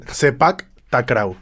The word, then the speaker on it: Sepak Takraw: Sepak Takraw. Sepak Takraw. S-E-P-A-K.